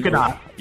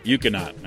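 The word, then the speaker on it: Ekuanot: How do you say that? – What's that? – E-K-U-A-N. – Ukianot. – Ukianot. All right, there you go.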